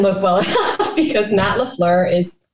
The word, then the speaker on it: LaFleur: Matt LaFleur. Did I say that right? LaFleur.